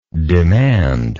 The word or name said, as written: demander